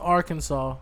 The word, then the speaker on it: Arkansas: And I know it is pronounced Arkansas.